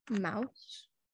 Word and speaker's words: Maus: I think it's pronounced mouse. M-A-U-S.